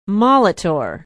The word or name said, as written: molitor